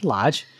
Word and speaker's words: Loge: And for folks interested, that's Lodge spelled L-O-G-E. Mm-hmm. It kind of looks like it's Loge, but it's pronounced Lodge.